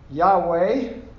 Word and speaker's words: Yahweh: To the Lord in your Bible, your English Bible, all capital letters, which is translation of Yahweh, more correct pronunciation.